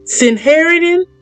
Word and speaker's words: Sanhedrin: I standing before the Sanhedrin.